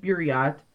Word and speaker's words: Buryat: It looks like Beirut, but it's not. Like, I looked up the pronunciation, so it's like Buryat people.